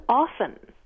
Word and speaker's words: often: O-F-T-E-N. – Do you pronounce the T or not? – Oh, right. – Right. Which way do you all do it? – It's been a point of snobbery for me that I never pronounce the T. I would never debase myself by saying the T. But now I hear people saying the T all the time.